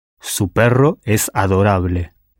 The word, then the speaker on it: perro: If the word for dog is perro, in Spanish spelled P-E-R-R-O, that R, think of a dog growling, that R, how would you say your dog is adorable?